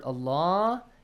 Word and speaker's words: Allah: The letter lam in the word Allah has to be pronounced with a light sound or heavy sound, Hussein? Ali Nasir? – Heavy. – Heavy sound. Because there is a dhamma sound before.